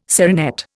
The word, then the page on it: serinette: Indian